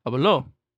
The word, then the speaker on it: habló: the word H-A-B-L-O can be pronounced hablo for I speak, or habló for he or she spoke. And like, that's more of a stress thing, but I mean, like, that plays into pronunciation.